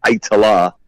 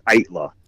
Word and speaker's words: Aittala: I got the promo there from Curtain Call Records there, and they have a little pronouncy thing that said, and it said E-Y-E-Y-E, right. – And then it had T-A-H, and then A-L-A. – I accept the three-syllable version because it's kind of easier, but it would be Itala. Acceptable pronunciation, but the official pronunciation is Itla.